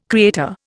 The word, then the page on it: creator: Indian